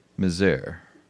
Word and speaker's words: misère: Misery in English, yeah. But in French, it's misère.